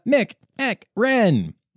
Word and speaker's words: McEchran: Anna Mick-Ick-Rin. Okay, so it's spelled differently, and she sent me a pronunciation, and it's McEchran, obviously, but she explained it as Mick-Eck-Rin, and we like to have fun in this section.